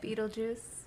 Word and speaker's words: Beetlejuice: So, good old how do you pronounce it? – Beetlejuice. Okay. Beetlejuice.